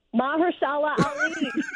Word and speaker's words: Mahershala Ali: How do you say his name? – Mahershala Ali.